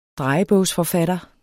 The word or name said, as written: drejebogsforfatter